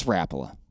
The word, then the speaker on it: Rapala: I was like, oh, snap, I've been saying it all wrong. It's Rapala.